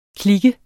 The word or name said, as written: klik